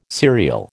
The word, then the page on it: cereal: American English